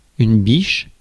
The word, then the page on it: biche: /biʃ/; France